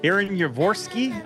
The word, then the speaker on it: Yavorsky: just who in the world is Aaron Yavorsky? Well, let's find out. Aaron Yavorsky? I'm saying it right, right? – Yes, sir.